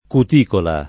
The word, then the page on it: cuticola: lingua italiana